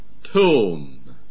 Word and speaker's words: tomb: Adjure.And on page 34, number 9, tomb. – 第34页的第9就是坟墓。 – The B is silent. Tomb.